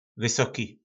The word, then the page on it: vysoki: po našimu